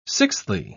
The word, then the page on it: sixthly: British English